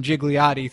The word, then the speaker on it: Gigliotti: The G is silent. We know that because we got to interview him. We'll play it here in a minute. We called him Gigliotti throughout the course of the interview because we're assholes.